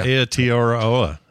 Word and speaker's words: Aotearoa: Aotearoa. – Oh yeah, Aotearoa.